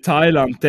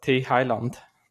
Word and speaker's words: Thailand: In Thailand, der dort, wo dort völlig durchdreht, die haben die... – Du hast falsch ausgesprochen, das heisst Thailand.